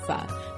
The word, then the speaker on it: pho: case you've been wondering how to pronounce that Vietnamese soup everyone's always going on about, it's officially pho, not pho.